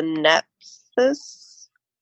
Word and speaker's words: Nephthys: Is the Nephthys? – Yes.